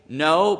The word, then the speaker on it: know: Know, believe, act. I know there's a silent K, but it doesn't fit my little letters there, okay?